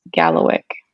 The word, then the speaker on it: kalawikk: And the word is actually diamond, but in Mi'kmaq, it's K A L A W I K K, and it is pronounced Gallowick.